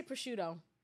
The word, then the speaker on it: prosciutto: How do you say prosciutto? – Prosciutto.